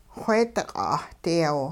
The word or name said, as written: wheediq' 'ahdiyaw